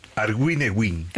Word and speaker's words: Arguineguín: Arguineguín. – Arguineguín.